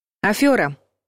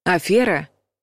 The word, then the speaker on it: афера: Афера – неправильный вариант. Афера – правильный вариант. Русский язык – тот еще аферист и ловит нас там, где мы не ждем. Лингвисты до сих пор отвечают на нападки, почему нужно произносить «афера», а не «афера».